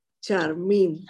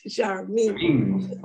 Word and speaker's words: Charmin: And the pronounce is Charmin. Charmin. – Charmin. – In Spanish, Charmin. – Well, that's it. In Spanish, they changed it. They put the accent here.